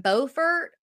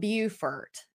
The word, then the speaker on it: Beaufort: It's spelled the same way, pronounced differently. We're in Beaufort. They're in Beaufort. It's two different places.